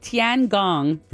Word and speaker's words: Tiangong: The Chinese company called Tiangong. – Very good pronunciation.